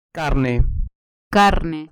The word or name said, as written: carne